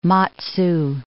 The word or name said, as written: Matsu